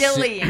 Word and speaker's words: Gillian: I think it's Gillian. Pretty sure it's a hard G, everybody.